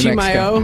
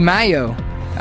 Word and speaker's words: Chimayo: How do you pronounce this city here in New Chimayo? – Mexico? Chimayo.